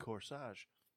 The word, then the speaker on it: corsage: I wear a corsage. – Very nice. – That's how you pronounce it. – It rhymes.